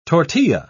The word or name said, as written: tortilla